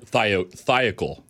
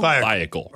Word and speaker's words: Thiokol: Thiokol. – Thiokol? – Thiokol.